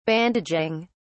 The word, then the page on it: bandaging: British English